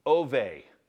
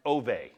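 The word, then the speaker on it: ove (אוב): The Hebrew word for medium is pronounced ove, ove. It's actually O-B. If you want the word for medium, O-B, and it's pronounced ove, like O-V-E, okay?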